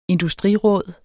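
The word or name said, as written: industriråd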